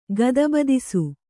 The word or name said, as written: ಗದಬದಿಸು